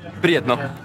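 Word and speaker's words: Prijatno: How do you say bon appétit in Serbia? – Prijetno. Prijetno. – Prijet? – Prijetno. – Prijetko. – Prijetno. Yeah. – That's perfect.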